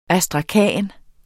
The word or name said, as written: astrakan